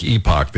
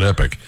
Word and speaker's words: Epoch: It's spelled like Epoch. They don't know how to pronounce Epoch, so they call it Epic. E-P-O-C-H.